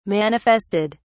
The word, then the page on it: manifested: British English